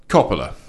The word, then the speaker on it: Coppola: We had Coppola, Capella, Capella, Coppola. – Coppola. Yes.